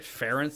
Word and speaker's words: Ferentz: Is that right? Ferentz? That's how you say it. – Yeah. It's like Ferentz. F-E-R-E-N-Z, I think.